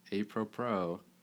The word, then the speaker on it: apropos: Bat and lead off, and how apropos, right? Is how you say it? – Mm-hmm.